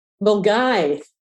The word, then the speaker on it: Milngavie: Yes, Bears Den in Milngavie with Clyde Bank. – Milngavie.